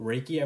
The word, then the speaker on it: Reiki: And, you know, on there, it's for the first time I recognized this word called Reiki, I believe is how you pronounce it.